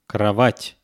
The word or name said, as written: krovat